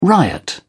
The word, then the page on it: riot: British English